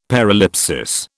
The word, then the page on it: paralipsis: British English